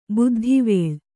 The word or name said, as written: ಬುದ್ಧಿವೇಳ್